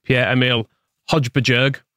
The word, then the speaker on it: Højbjerg: And then I've got Spurs player, who I believe it's pronounced Pierre-Emile Højbjerg. – Yep, correct. Well done.